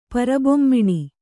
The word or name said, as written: ಪರಬೊಮ್ಮಿಣಿ